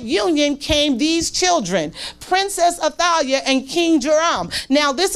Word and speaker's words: Athaliah: Now, different accounts say different things, but the wickedness that you see from Athaliah, that's how you say her name, Athaliah, the wickedness you see, I am led to believe that Jezebel was her mother.